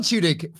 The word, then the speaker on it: Tudyk: How do you say Tudyk. – His name? – Alan Tudyk. – Tudyk.